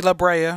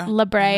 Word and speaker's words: La Brea: Is it how you say it, babe? – La Brea. – La Brea. Yeah.